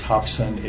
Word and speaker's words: Hopson: Last name is spelled Durick, D-U-R-I-C-K. – Mark Hopson from Sidley Austin, representing Dick Siren. It's Hopson, H-O-P as in Paul, S-O-N. – Dick Siren, representing myself. Last name is S-Y-R-O-N.